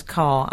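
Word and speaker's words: Kerr: Well, that doesn't surprise me, because what you wouldn't know is that Deborah Carr made it clear that her surname should always be pronounced the same as Carr, as in the vehicle.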